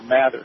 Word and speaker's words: Mather: Another thing which is fascinating, David Ogilvy of Ogilvy and Mather, or Mather, some people pronounce it, he was the advertising gentleman of Ogilvy and Mather, Mr. Ogilvy, and become advertising executive.